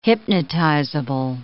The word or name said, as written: hypnotizable